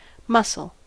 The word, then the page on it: mussel: American English